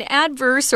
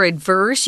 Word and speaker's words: adverse: Yeah, an adverse or adverse, you can pronounce it either way, just means bad, harmful, unfavorable.